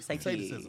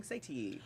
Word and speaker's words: satiety: Look. Morning satiety. – Oh, yeah. That's right. Yeah. You said it right.